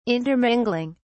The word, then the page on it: intermingling: British English